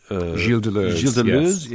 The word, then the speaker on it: Gilles Deleuze: You quote the philosopher, how do you say, Gilles Deleuze? – Gilles Deleuze, yes.